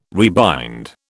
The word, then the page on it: rebind: British English